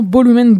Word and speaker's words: Volumen: parce que V se dit B en espagnol, donc je vais essayer de le prononcer correctement, Volumen Brutal.